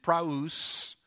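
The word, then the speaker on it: praus (πραΰς): Actually, in the Greek, and I'll give you a Greek word just because you'll know in a minute, but the word is praus. Praus, there it is, spelled out for you phonetically.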